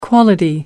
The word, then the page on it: quality: American English